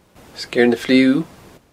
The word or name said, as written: Sgeir na Fliugh